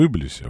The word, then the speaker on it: Иблис: Анзор поправляет ударение в Иблисе, да? Не в Иблисе, а в Иблисе.